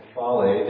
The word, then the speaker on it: kephale: This would be with a long E, kephale, that is the Greek word for head.